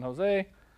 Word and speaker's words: Hoezee: Brandon Jose of Jose History Productions YouTube channel. It's spelled H-O-E-Z-E-E.